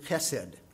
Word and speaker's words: chesed: It's the Hebrew word chesed, H-E-S-E-D, or C-H-E-S-E-D. It's a guttural sound that we don't have in English. I heard a comedian one time say that pronounce that letter, it's like you swallowed a fishbone and you're trying to hack it up out of your throat. It's this chesed sound.